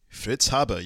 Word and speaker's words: Fritz Haber: How do you pronounce it? – Fritz Haber, yeah. – Yeah, okay. – You got it.